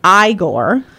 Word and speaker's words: Igor: Frederick Frankenstein, into the spotlight as he inherits his family's eerie estate in Transylvania, alongside his peculiar sidekick, Igor, pronounced Igor.